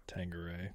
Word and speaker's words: Tanqueray: That's like a Q, so it's like Tank. Kia. Tanqueray? – I believe the rappers call it Tanqueray.